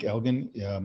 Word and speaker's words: Elgan: Okay, so I'm on Twitter, Mike Elgan. My last name is spelled E-L-G-A-N.